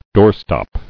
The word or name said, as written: Doorstop